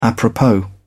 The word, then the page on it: apropos: British English